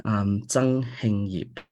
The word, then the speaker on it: Zhang Heng Yip: But in Chinese, it's read Zhang Heng Yip.